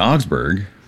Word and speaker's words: Augsburg: And it is interesting to note just how many articles there are in the Augsburg, which is 28.